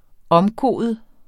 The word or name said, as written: omkode